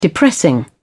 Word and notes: depressing: British English

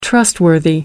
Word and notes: trustworthy: American English

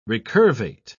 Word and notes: recurvate: British English

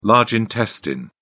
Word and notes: large intestine: British English

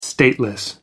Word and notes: stateless: American English

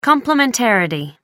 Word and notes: complementarity: American English

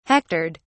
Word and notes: hectored: British English

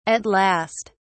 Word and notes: at last: British English

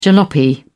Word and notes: jalopy: British English

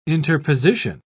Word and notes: interposition: British English

